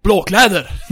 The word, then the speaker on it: Blå Kläder: And it was like, Blå Kläder. Blå Kläder. And then the Swedish guy was like, no, it's Blå Kläder. He was annoyed he couldn't pronounce it right.